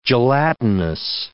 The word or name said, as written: gelatinous